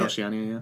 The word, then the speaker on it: Oceania: all about the art and culture of Oceania. Is that how you say it? – Oceania, yeah.